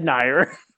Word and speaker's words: Nier: She totally said Nier. – Yep, I'm not the only ones. Fuck you guys. – That's the correct way to say it.